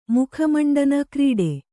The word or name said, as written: ಮುಖಮಂಡನ ಕ್ರೀಡೆ